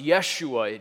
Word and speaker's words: Yeshua: It comes from the Hebrew name Joshua, or pronounced Yeshua.